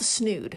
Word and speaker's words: snood: Uh, it's called a snood. Yes, it's called a snood. It's awesome. It rhymes with nude.